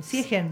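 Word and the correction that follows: Sjögren: El síndrome de Siegren. – Siegren.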